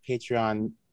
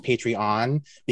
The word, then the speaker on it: Patreon: I didn't realize that Patreon in like North American vernacular is pronounced Patreon, because the first experience I had with Patreon was with Diver Tom Daly on his YouTube channel. And since he is British, he says it is Patreon.